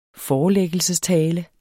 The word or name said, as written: forelæggelsestale